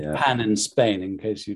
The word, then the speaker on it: pain: and pain in France, where it's pronounced pan.